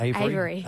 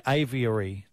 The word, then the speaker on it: aviary: Avery. – Avery? No, aviary.